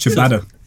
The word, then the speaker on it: ciabatta: You ever tried chia batter? – Yeah, no, garlic bread, isn't it? – No. – It's pronounced ciabatta.